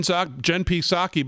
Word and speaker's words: Psaki: And Jen Psaki, by the way, P is silent.